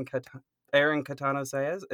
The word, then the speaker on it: Catano-Saez: our guest for today is Aaron Catano-Saez.